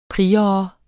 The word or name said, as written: prior